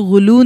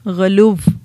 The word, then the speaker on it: غلو: اچھا، اس کو غلو نہیں کہنا، واو آخر میں پرونانس کی جاتی ہے، غلو، ٹھیک ہے.